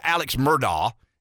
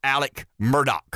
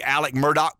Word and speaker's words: Alex Murdaugh: It's expelled Alex Murdaugh. It's pronounced Alec Murdaugh. Why do we say Alec Murdaugh? Because that's what he says. And when they ask him the name, he says Alec Murdaugh.